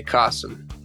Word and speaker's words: Kasim: It's like awesome with a K, Kasim.